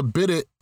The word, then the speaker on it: bidet: It's, a bidet.